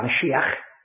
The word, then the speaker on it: Mashiach: Of course, you can realize that the word Messiah is perhaps a Hebrew word, although it wouldn't be pronounced Messiah. It would be more like Mashiach.